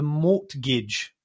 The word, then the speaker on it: mortgage: And today we are talking about the madness that is the market right now, and in particular, the mortgage. Yeah, because there's a silent, what's silent, the G or the T? – Mortgage, it's the T. – The T is silent.